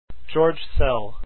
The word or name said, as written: Szell